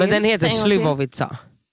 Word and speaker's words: Slivovica: Alltså jag tror att ni fick en Slivovica. – Slivovica? – Ja, exakt.